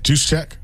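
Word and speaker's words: Juszczyk: It's like J.U., and then he just throws all the letters of the alphabet after it. Yeah. How do you say it? – Juice check. – Is that right, or are you just saying that? – I think that's right.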